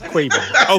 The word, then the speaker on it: Quavo: How do you pronounce his name? – It's Quavo.